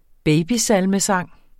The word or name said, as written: babysalmesang